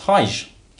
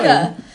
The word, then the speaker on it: Hygge: I thought it was pronounced Hyge. I've been pronouncing it Hyge this whole time. – Hygge.